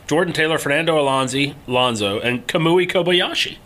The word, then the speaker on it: Kamui: Is it Kamui? – It is Kamui.